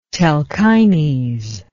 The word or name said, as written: Telchines